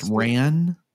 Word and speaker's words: Wren: And it's pronounced Ran. – Well, it's spelled Wren, but they pronounce it Ran.